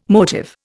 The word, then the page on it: motive: Indian